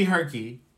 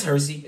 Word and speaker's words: Hersey: No, it's Hersey. It's Hersey. – It's Hersey? Really? – Mm-hmm. Why would it be Herky? – You saying like the pronunciation wise? – Yeah. – Nah, because it's spelled the same way as Percy with an H.